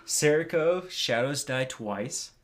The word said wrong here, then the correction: Sekiro: I don't think you said it right. Sekiro? – Oh, Sekiro. Sorry.